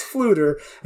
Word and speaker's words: Fflewddur: I just wanted to mention that Fluter Flam's name is spelled F-F-L-E-W-D-D-U-R. That's Fluter, and Flam is F-F-L-A-M.